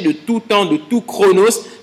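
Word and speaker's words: chronos (χρόνος): Quand il dit le mystère caché de tout temps, dans le livre de Colossiens, le mot « temps » ici, c'est un mot grec que l'on prononce « chronos ».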